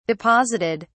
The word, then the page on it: deposited: British English